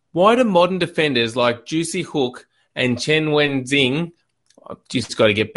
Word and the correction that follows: Chen Weijing: Chen Weijing.